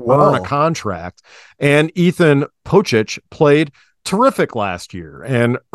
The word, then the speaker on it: Pocic: Think of like Stipe Miocic is CIC. – I can't do that. – Yeah, his last name is CIC, just like Ethan Posick's name is CIC. and his name is pronounced Miocic. So it makes sense that Ethan Pocic's name is also pronounced Pocic.